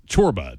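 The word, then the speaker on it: Csorba: song there from Thomas Chorba called The Big Time. Apologies for people who already know, but he's got a kind of difficult to spell last name. It's C-S-O-R-B-A, pronounced Chorba.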